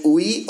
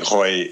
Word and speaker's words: Huy: There's two different pronunciations, though. There's the Huy. – But only one is right. – And No. One of them is in Flemish, and then the other one is in French.